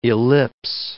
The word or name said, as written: ellipse